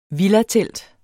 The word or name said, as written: villatelt